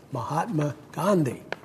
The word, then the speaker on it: Mahatma Gandhi: Recently, I came across a quote attributed to Mahatma Gandhi. I didn't say that right. Mahatma Gandhi. Got an extra syllable in there.